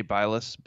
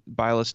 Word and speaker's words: Bilas: I think there was one drive that Jay Bilas, mm-hmm. I forget. – It's Bilas. You know, yeah, y'all know how bad I am at pronouncing names, but there was one drive that Shannon.